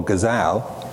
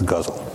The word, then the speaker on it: Ghazal: He was a preeminent master of the poetic form that I used to call Ghazal, but now know better and pronounce Ghazal.